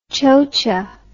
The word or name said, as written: chocha